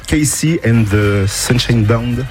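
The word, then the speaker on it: KC and the Sunshine Band: Casey and the Sunshine Band. – C'est ça. – Je l'ai bien dit ? – Oui, à peu près. Sunshine Band.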